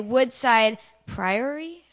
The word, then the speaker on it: Woodside Priory: And the high school boys play Oakwood and Morgan Hill on Tuesday and the high school boys play Woodside Priory.